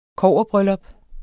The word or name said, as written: kobberbryllup